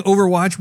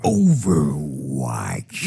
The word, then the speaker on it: Overwatch: In addition to that, I've been playing Overwatch with you. – You didn't say it right. But I forgive you. I forgive you. – Please say it for me. – Overwatch. – That's perfect. Thank you.